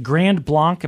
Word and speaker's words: Grand Blanc: And there's a place in, what is it, Grand Blanc? Am I saying that right? – Sure, yeah. – Grand Blanc, Michigan.